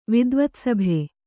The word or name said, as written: ವಿದ್ವತ್ಸಭೆ